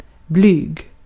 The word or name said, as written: blyg